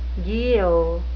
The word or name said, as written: guyot